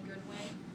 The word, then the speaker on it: Goodwin: Marie Simonetti Goodwin. It's M-A-R-I-E-S-I-M-O-N-E-T-T-I and G-O-O-D-W-I-N.